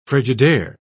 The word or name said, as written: frigidaire